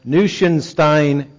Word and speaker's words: Neuschwanstein: And this castle was built in the late 1800s, and it's known as Neuschwanstein Castle. If I tried to spell it for you, it wouldn't look anything like I just pronounced that word, but that is the correct pronunciation of the word.